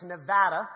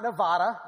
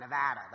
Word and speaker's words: Nevada: And it is how you say it. It's Nevada. So if you want to sound educated, it's not Nevada. I know that's kind of almost like what it sounds like it would be. Nevada almost sounds more East Coast, but that's the proper pronunciation. Just think Aflac, Nevada. That's how you say it.